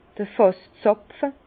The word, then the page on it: d'r Fàsszàpfe: Bas-Rhin; Herrlisheim